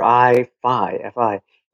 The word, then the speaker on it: Eye-Fi: I kept doing a web search for the letter I, FI. I'm like, what are you talking about? I completely forgot because I used to review all the products from iFi, but it's spelled EYE-Fi.